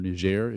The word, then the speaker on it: Niger: The two other places that we're thinking about are Galmi, Niger, or Niger, if you want to do the French pronunciation, and Kajabe, Kenya.